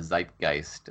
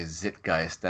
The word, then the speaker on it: zeitgeist: It annoys me when people say zitgeist. I'm glad you're not a zitgeist guy.